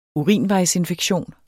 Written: urinvejsinfektion

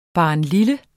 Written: barnlille

